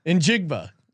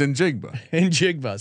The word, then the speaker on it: Njigba: How do you pronounce Smith dash Njigba? What is it? – Njigba. – Okay, that's not a real name. – yeah, sure it is. All right. It's Njigba.